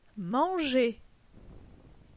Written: Manger

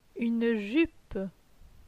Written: Une jupe

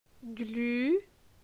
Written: Glu

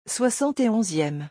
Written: 71eme

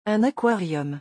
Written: Un aquarium

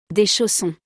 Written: Des chaussons

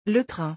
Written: le train

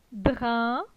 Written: Drin

